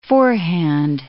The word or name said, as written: four-hand